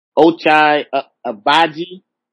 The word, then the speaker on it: Ochai Agbaji: Ochai Agbaji.